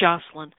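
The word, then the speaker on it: Joshilyn: And first of all, her name is spelled Joshylyn, but it's still pronounced Jocelyn. Her book is The Almost Sisters.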